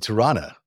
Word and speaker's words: Tirana: Our first guest from Tirana.